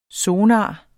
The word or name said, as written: sonar